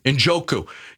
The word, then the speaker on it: Njoku: Njoku.